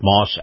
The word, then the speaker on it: Maus: This portion of the program brought to you by our friends at Moss Acura. Moss is spelled M-A-U-S.